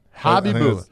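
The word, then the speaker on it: Khabibulin: Nikolai Habibulin. – Habibulin. – That's how you say it.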